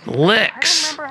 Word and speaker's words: Lixx: And I looked him up, and he was in this band called Licks. With two X. It's spelled with the 2X kind.